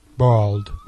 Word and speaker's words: bald: Bald diye yazılıyor, bold diye okunuyordu. Bold, kel. – Bald. – Bald.